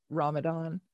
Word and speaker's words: Ramadan: So does it have anything to do with the celebration of and there are two different pronunciations of Ramadan? – And but that's the correct one.